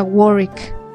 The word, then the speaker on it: Warwick: Bueno, se escribe Warwick porque era el conde de Warwick, pero en realidad se pronuncia Warwick.